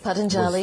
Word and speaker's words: Patanjali: So Patanjali, is that how you say it? – Patanjali.